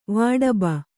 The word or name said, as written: ವಾಡಬ